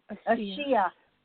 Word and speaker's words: Ashia: Ashia. Yes, ma'am. – Yeah, it's Ashia. – Ashia. Yeah, Ashia. Ashia, yeah. With an A in front of it.